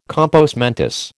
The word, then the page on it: compos mentis: American English